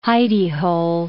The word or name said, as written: hidey-hole